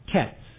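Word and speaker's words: kets (קץ): So the word for fruit is kayits, Q-A-Y-I-T-S, and the word for end is kets, K-E-T-S, Q-E-T-S. So kayits and kets, they sound very familiar, very different words, summer fruit and end.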